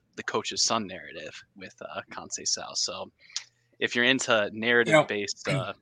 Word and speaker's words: Conceição: Francisco Conceição. I've learned to give those C's the S pronunciation there.